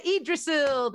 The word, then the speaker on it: Yggdrasil: Yeah, Yggdrasil.